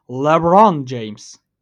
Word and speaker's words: LeBron James: Hatta daha doğru telaffuzla LeBron James.